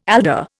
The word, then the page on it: alder: Indian